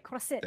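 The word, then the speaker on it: croisette: Did I pronounce it okay? – You did good. French. You did great. Crosette.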